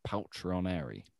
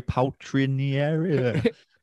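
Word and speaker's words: Paltrinieri: And endurance, we have just gone Gregorio Palchineri. – Yes, correct. Well done for pronouncing that correctly. – Yeah. I had to really concentrate there. – It's so slow. Palchineri.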